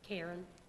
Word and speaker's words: Karyn: Karen. – Karen, how do you spell it? – K-A-R-Y-N.